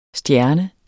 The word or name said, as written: stjerne